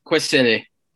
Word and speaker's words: Kwesele: Kwesele.